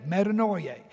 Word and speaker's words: metanoia: Like if you were going to write that just kind of like we would pronounce it, it'd be M E T A, meta, then noia, N O I, and then dash A, metanoia.